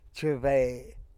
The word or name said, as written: chebe